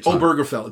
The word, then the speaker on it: Obergefell: Obergefell, that's it, Obergefell, it's pronounced Obergefell, which granted the right of same-sex marriage to same-sex couples.